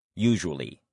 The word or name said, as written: usually